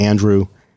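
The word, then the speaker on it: Andru: Instagram is just Andrew, and Twitter is Andrew Edwards, all one word.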